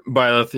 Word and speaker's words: Byleth: The salt was so good at the end of the first Smash Battle Pass when Bleth, I don't know how to pronounce the name. – Byleth, yeah. Byleth, it's just...